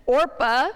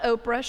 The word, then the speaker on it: Orpah: And this is where Naomi and her husband and her two sons take on two new family members, two Moabite women, Orpah. I did not say that wrong. She's not Oprah, she is Orpah.